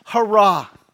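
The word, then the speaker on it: hurrah (χαρά): But in the Greek, it's pronounced hurrah. Hurrah, right?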